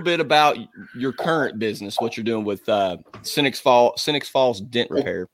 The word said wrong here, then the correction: Sioux Falls: So it's pronounced Sioux. – Sioux Falls.